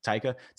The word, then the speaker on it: Taika: Taika Waititi.